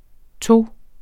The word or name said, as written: to